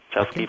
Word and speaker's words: Chasqui: Here's, and correct me if I pronounce it wrongly, Chasqui from Leyendas. – That's right. Chasqui okay.